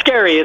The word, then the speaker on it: Scarry: Yes, Scarry. It's like Carrie with an F.